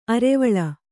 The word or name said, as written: ಅರೆವಳೆ